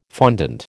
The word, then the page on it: fondant: American English